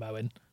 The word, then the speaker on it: Eoghan: I would like to point out to listeners that Steve is mispronouncing his name on purpose. Please don't tweet us. It'll be quite boring. But Steve is doing that on purpose. He finds it funny. I find it vaguely amusing. I'll probably just call him Owen.